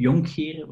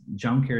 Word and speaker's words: Jonckheere: So it's Louis Jonkheer, but it's impossible to pronounce in English. So it's Jonkheer is fine.